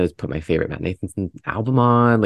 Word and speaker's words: Nathanson: Na- Nathan, did I say it right? Nathanson. Mm-hmm. But like literally yesterday, like I listen, I love listening to him in the fall.